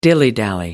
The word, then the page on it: dilly dally: American English